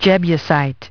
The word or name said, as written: Jebusite